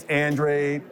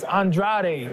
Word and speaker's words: Andrade: In my country, they pronounce my last name as Andrade. Mm-hmm. And so when they see me as Andrade, that sounds pretty sexy to me. – Yes. – Right? And in America, it's pronounced Andrade.